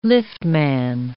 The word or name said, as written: liftman